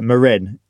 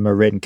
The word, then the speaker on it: Marin: So Marin, as you're supposed to call it, because it's from Marin County, not Marin, or you naughty British people.